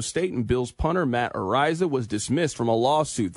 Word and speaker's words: Araiza: The Chiefs are signing former Bills punter Matt Araiza, is how you pronounce his last name.